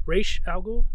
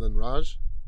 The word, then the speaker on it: Ra's al Ghul: How do you say that? – Ra's al Ghul. – Is that different from Raj? – I hear both. Okay. I used to say Raj for the longest time until like 2-3 years ago.